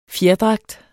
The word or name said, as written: fjerdragt